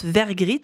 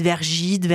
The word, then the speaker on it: Vergriette: Eh bien, parce que mon nom de famille se prononce Vergrit, mais il s'écrit Vergriette, en fait, parce qu'il y a un E-T-E à la fin. Déjà, voilà, c'est imprononçable. Les gens, ils n'y arrivent pas. Ils disent Vergide, Vergette, Vergillette.